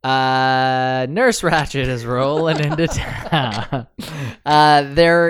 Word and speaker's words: Ratched: Do we pronounce it Ratched? Like, yeah. – Ratchet. Ratched. – I think it's Ratched.